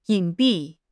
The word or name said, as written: 隐蔽